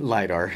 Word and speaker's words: LIDAR: How do you pronounce it? – LIDAR. – LIDAR, okay.